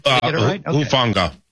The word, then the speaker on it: Hufanga: Hufanga.